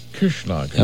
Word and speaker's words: Kirchschlaeger: Rudolf Kirschlager. – Oh, very good. Very good indeed. And it's spelled K-I-R-C-H-S-C-H-L-A-E-G-E-R.